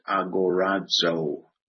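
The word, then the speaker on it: agorazo (ἀγοράζω): And again, it has the Greek word, and then to the right, it'll have the transliteration as to how you would pronounce it, agorazo, long O.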